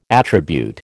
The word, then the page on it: attribute: American English